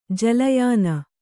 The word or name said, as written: ಜಲಯಾನ